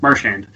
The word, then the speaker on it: Marchand: Because he'd actually, you know, talks to him and he's like, yeah, Brad's like, no, I'm from Nova Scotia, we don't pronounce it right. It's Marchand.